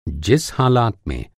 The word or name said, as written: हालात